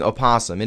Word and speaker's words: opossum: And I actually, the Didelphus virginianus, which we know commonly as the opossum, I usually pronounce the O. I think there's actually quite a few people who do that to differentiate it from the other creature, which is not in North America.